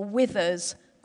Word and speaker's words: whither: But it's also not the wither that describes what happens when a fruit shrivels or withers on a vine. Instead, it's wither with a silent H because the first time that I heard the story of Ruth read was from the authorized King James Version of the Bible.